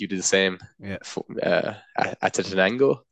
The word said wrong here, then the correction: Acatenango: Is that how you pronounce it, Rory? – Acatenango, yeah.